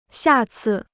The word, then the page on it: 下次: xià cì